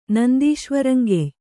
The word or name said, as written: ನಂದೀಶ್ವರಂಗೆಯ್